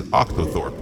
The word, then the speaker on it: octothorpe: Octotroph. – Yep. Producer Forever here. The correct pronunciation is Octothorpe.